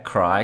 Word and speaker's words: Kry: Alex is now a talent partner and technical recruiter at Cry. Cry is spelled K-R-Y and is Europe's largest medical provider via app.